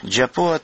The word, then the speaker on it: Джапуа: Джапуа, так она произносится и пишется по-абхазски.